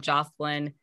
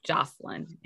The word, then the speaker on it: Jocelyn: Just look up Jocelyn, actually connect with Jocelyn. And my name is spelled J-O-C-E-L-Y-N.